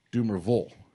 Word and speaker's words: Dumervil: By the way, the name is pronounced not Dumervil or Dumervil. He says it's Dumervil. – Dumervil. – Dumervil, which I stand corrected.